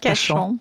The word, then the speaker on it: Kashan: Kashan.